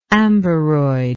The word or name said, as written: amberoid